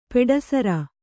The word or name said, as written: ಪೆಡಸಾರ್